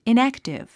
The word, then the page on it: inactive: inǽktiv; イナァクティブ